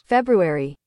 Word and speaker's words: February: Speaking of pronunciation and the internet, here is how February, I just said it wrong, is pronounced according to Google. – Yes. – February. – See, it's you do say the R.